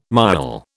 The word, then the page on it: miaul: British English